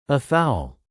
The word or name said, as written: afoul